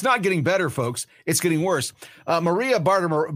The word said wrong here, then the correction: Bartiromo: had, um, I cannot say her name, by the way. Maria Bartiromo, had um, James Comer and Jim Jordan on this last weekend and had some very specific questions that she wanted answered.